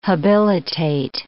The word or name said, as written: habilitate